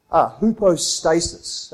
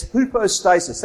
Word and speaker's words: hypostasis: That word substance in the Greek is hypostasis. That's how you pronounce it in the Greek, but I'm saying it with an Australian accent. So it's hypostasis, that word substance, the substance of things hoped for.